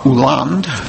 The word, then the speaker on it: Hollande: And his opponent in the race, François Hollande, spelled with an H, but the H is silent, François Hollande, who's now the new president, had also left his wife to marry the woman he's currently married to.